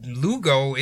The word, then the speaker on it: Lugo: Ponzi was born on March 3rd, 1882 in Lugo, Italy. I assume that's what it's called, right? – Oh, yeah. – You would be correct.